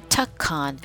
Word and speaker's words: Tekun: And then the general at that time, General Tekkan. That's the first time I'm mentioning this character. It's spelled T-E-K-U-N.